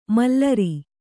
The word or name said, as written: ಮಾಲೇರು